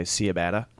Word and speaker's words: ciabatta: Ciabatta.